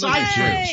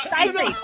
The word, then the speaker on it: Seyfried: Amanda Seyfried from Mean Girls. – It's Seyfried. She pronounced her name Amanda Seyfried, but I didn't. – Oh, it's Seyfried. Of course you know that.